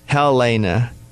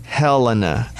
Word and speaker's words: Helena: Most people say Helena, but it's Helena.